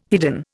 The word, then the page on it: hidden: Indian